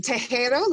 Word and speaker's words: Tejero: It's Tejero, like te quiero in Spanish, which means I love you.